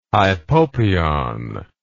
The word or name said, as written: hypopyon